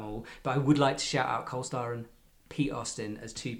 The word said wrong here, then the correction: Cole Styron: Should I say his names again? – Cole Styron. – Cole Styron, yeah.